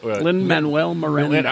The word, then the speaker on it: Lin-Manuel Miranda: It's Lin Miranda. – Lin-Manuel Miranda.